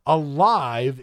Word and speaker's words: Alive: For example, on Tuesday, March 15th, there's a film that is from Japan that is called Adam by Eve Alive in Animation. And I didn't mispronounce that. That is A-L-I-V-E, not a life in animation.